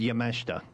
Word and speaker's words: Yamashita: I said it before Yamashita, but her name's Yamashita. – Is it? – That's how you pronounce it.